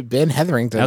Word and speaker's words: Ben Hetherington: Ben Hetherington. – Okay. – Yes, you fully skipped at least one syllable.